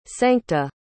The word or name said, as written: sancta